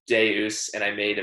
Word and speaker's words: Deus: Forrest tells Lily about halfway through the episode that it's the Roman V, so it's actually pronounced like a U, and so it's not called Devs, it's called Deus, which means something.